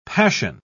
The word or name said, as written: passion